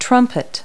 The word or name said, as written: trumpet